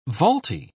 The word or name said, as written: vaulty